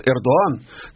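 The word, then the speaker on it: Erdoğan: И тогда, в начале 90-х, это практиковало запрещенная с тех пор, ну, супернационалистическая партия, а тогда Эрдоган, или, как его турки называют, Эрдоган, там Г не произносится, да, она с птичкой такая буква, Эрдоган в качестве мэра Стамбула, он, вообще говоря, с этим боролся.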